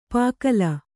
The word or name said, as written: ಪುಕ್ಕಲಿ